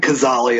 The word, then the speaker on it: Cazale: Let's talk about Fredo, John, because I think Cazale is how you pronounce it.